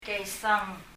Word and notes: keisang: kɛisaŋ

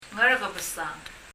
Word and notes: Ngerkebesang: アラカベサン

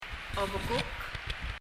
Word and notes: obekuk: ɔbɛkək

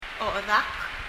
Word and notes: ochedak: ɔ ? ɛðək

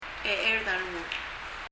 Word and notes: checherd a rengul: [? ɛ ? ɛrðəŋ(ə)l]

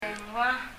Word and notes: dengua: [dɛŋua]

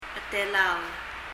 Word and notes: chetelaol: ? ɛtɛlaɔl

